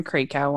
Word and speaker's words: Krakow: Yeah, and definitely that Jill Wagner one, and definitely the Aaron Krakow one. – Mm-hmm. Krakow. – Krakow? Oh. – Yeah, like I'm Draco.